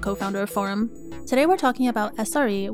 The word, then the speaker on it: Forem: Dev is still alive and well, but we announced recently that the underlying software that powers Dev is called Forem. That's spelled F O R E M, and it stands for the phrase for empowering community.